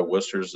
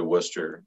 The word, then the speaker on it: Worcester: And I always thought it was Worcester. I couldn't even say it right. You know, down here, I actually have pool projects on a street spelled exactly the same thing, but it's Worcester. It's not Worcester.